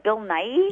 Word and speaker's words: Bill Nighy: And although it's very forgettable, it had some lovely moments, especially, and I think I'm saying this right, Bill Nighy? – Yeah.